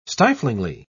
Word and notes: stiflingly: British English

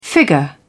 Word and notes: figure: British English

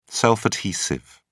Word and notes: self adhesive: British English

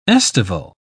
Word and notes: aestival: English; British English

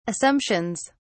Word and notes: assumptions: British English

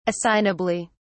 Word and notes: assignably: British English

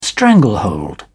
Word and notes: stranglehold: British English

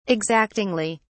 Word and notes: exactingly: British English

